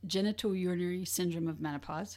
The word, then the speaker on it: genitourinary syndrome of menopause: So it's called genitourinary syndrome of menopause. Have I said that correctly? Yes, or GSM. – Yes, you do.